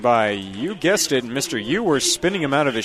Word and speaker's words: Euers: Yeah, Ewers. His name is spelled E-U-E-R-S, so I'm just trying to get a proper pronunciation on that.